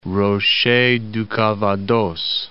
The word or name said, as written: Rochers du Calvados